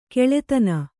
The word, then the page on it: ಖಳಧಾನ: khaḷa dhāna